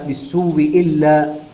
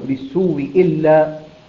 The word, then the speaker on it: بالسوء إلا: فيصير النطق بواو مشددة مكسورة هكذا بالسوء إلا، بالسوء إلا، نعم تقرأ بتشديد الواو وتكون الواو مكسورة أيضا بالسوء إلا، بالسوء إلا، وطبعا بعدها تكون همزة محققة، وهو الأشهر والمقدم في الأداء، الأشهر والمقدم في الأداء هو هذا الوجه، نعم بالسوء إلا.